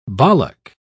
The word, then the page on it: bollock: British English